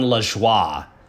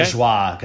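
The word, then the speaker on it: Lajoie: I think it's pronounced John LeJoie. – LeJoie? – Okay. LeJoie, because I think it's a French Canadian name, and he looks familiar to me.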